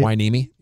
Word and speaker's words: Hueneme: And I think it says Port Hueneme. Is that how you say it? – Hueneme. Yeah. – Hueneme.